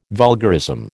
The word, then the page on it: vulgarism: American English